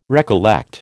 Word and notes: recollect: American English

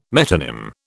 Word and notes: metonym: British English

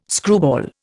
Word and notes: screwball: Indian

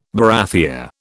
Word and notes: barathea: British English

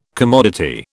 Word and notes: commodity: British English

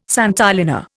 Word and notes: santolina: Indian

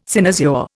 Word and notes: cynosure: Indian

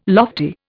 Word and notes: lofty: Indian

